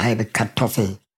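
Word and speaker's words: Halbe Katoffl: Für die ZuhörerInnen, die ihn noch nicht kennen, Halbe Kartoffel schreibt man Halbe, aber dann Kartoffel, K-A-T-O-F-F-L. Man lässt das R und das E von Kartoffel quasi weg und rauskommt Halbe Kartoffel.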